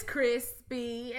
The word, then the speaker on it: Chrispy: My name is Crispy, and that's spelled C-H-R-I-S-P-Y, also known as Lando Calrissian, also known as the lady who bounced it up and down like a trampoline.